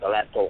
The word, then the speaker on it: salat: Which has the essential, first of all, let's say that we normally spell it S-A-L-A-T, right? Salat. – Yes, yes, yes. – But yes. – It is really salat.